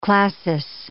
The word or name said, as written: classis